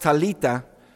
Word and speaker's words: Talitha: In Aramaic, this word Talitha, pronounced Talita, is also a synonym for little lamb.